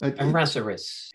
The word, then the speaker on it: Errázuriz: How do you do Errázuriz? – Errázuriz. If you can roll your R's, which I can't, which is one of the many reasons I can't actually speak Spanish, the other being I'm an idiot.